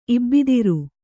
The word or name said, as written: ಇಬ್ಬಿದಿರು